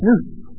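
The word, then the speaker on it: noose (νοῦς): The Greek word for mind, which, if you want to remember it easily, it's kind of pronounced like a noose. You know, like you'd hang somebody with a noose. It's noose.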